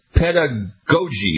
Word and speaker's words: pedagogy: Got Dr. Pamela Pike, a professor of piano pedagogy.